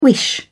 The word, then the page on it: wish: British English